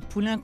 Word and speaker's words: Poulain: Poulain comme un poulain, ça s'écrit P-O-U-L-A-I-N.